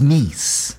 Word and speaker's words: Nice: It is pronounced Nice.